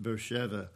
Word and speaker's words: Beersheba: Then she departed and wandered in the wilderness of Beersheba, but it's actually pronounced Beersheba.